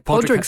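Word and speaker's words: Pádraic: I mean, I will give you the fact that Colin Farrell's character is Podrig, which is not spelled with a G. – How's it spelled in this one? – It's like Podrig spelled. Yeah, like Padraic.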